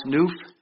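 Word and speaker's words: nuph: It's N-U-P-H, pronounced noof, like a hoof, except it's a noof.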